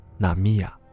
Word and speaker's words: 浪矢: 在日文中，浪矢念作纳米亚，而烦恼。念作纳亚米。